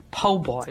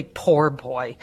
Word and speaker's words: Po' Boy: And we are taking you today to Domalisi's Po' Boy, which is spelled P-O-B-O-Y. Do not say poor boy.